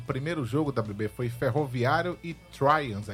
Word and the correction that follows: Tramways: é isso que pronuncia? – Tramways.